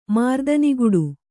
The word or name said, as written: ಮಾರುದನಿಗೊಡು